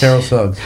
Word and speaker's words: Terrell Suggs: Ex-NFL star Terrell Suggs, did I say that right? – Yes, Terrell Suggs.